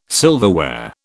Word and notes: silverware: British English

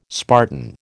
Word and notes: Spartan: American English

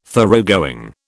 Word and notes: Thoroughgoing: British English

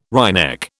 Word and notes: wryneck: British English

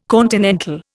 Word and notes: continental: Indian

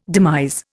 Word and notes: demise: Indian

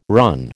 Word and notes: run: American English

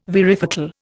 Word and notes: varifocal: Indian

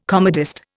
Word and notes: comedist: Indian

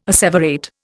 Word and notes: asseverate: Indian

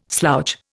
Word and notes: slouch: Indian

